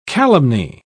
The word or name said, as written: calumny